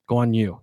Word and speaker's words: Guanyu: Guanyu, is that how you say it? Joe Guanyu. – Guanyu, yes. And Oscar Piastri at plus 550 rounds it out there.